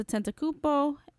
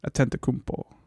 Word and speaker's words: Antetokounmpo: You pronounce that so well. – Antetokounmpo? – Yeah. I've tried to say it, and I feel like I can't. – Say it. – Antetokounmpo. – That's pretty good.